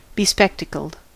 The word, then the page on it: bespectacled: American English; [bɪ.ˈspɛk.tə.kəld]